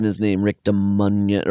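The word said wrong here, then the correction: Ducommun: or whatever. – Dukeman, I think. – Dukeman, thank you. Yes. I was like saying some weird like Dominion variation.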